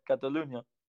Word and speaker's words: Catalunya: Catalunya. Is that right? – Catalunya. – Yeah, Catalunya.